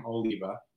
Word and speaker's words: Oliva: Oliva.